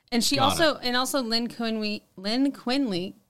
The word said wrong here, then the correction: Quigley: I can't even say her name. Lynn Quigley? – Sure. Quigley.